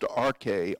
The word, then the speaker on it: arche: It's from a really curious Greek word pronounced arche, A-R-K-E, not A-R-C-H-E.